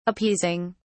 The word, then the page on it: appeasing: British English